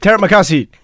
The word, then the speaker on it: Terima kasih: So this is how you say thank you. Terima kasih. – Terima kasih.